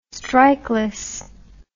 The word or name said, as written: strikeless